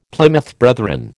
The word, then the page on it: Plymouth Brethren: American English